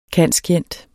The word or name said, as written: cand.scient.